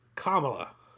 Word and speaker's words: Kamala: And our grandmother p- correctly pronounces, which is something I have trouble doing, Kamala.